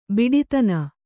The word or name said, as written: ಬಿಡಿತನ